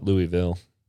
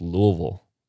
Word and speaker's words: Louisville: It's not Louisville. It's not Louisville. It's Louisville. You just slur it together.